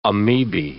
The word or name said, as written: amoebae